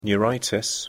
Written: neuritis

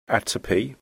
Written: atopy

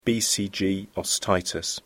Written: BCG osteitis

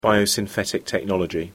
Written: biosynthetic technology

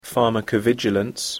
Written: pharmacovigilance

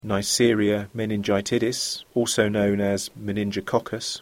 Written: neisseria meningitidis

